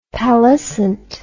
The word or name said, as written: pallescent